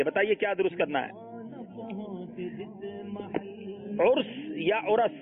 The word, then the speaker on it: عرس: کسی بزرگ کی سالانہ فاتحہ کی جو مجلس ہوتی ہے عرس کہا جاتا ہے, عرس نہیں ہے.